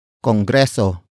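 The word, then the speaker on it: Kongreso: Congreso.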